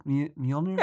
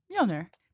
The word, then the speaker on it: Mjolnir: Is that how you say it? Mjolnir? – Yeah, Mjolnir.